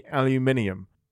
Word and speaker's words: aluminium: I can say aluminium because there is an I aluminium. – In there. Aluminium.